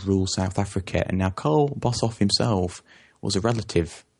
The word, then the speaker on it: Carel: Anyway, Orania itself was founded by a chap called Carl Bossoff the Third. Now, if you look at the English spelling, it looks like Carol. It's spelled C-A-R-E-L, but it's actually Carl.